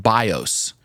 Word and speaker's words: bios: Actually, the word there is bios, but it's spelled B-I-O-S, like bios, but you pronounce it bios.